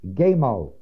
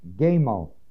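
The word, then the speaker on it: gemo (γέμω): The word full is the word gemo, G-E-M, long O, gemo. I imagine most of you core thought it'd be polaco, maybe a little Plato. It is an entirely different word. It's the word G-E-M-O, long O.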